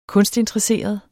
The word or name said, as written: kunstinteresseret